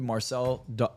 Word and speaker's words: Marczell: So you can find me on YouTube, Marczell Marczell Klein, M A R C Z E L L.